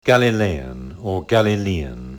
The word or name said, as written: Galilean